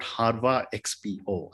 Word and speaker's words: HarVa XPO: So Ajay, let's start talking about HarVa XPO. I hope I've got the pronunciation right. – Absolutely, yeah.